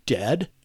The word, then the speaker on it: Dehd: So I hung out in the tent for a bit and watched Dead, and that's spelled D-E-H-D.